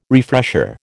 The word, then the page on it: refresher: American English